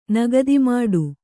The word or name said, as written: ನಗದಿಮಾಡು